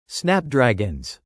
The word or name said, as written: snapdragons